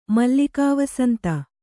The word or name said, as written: ಮಲ್ಲಿಕಾವಸಂತ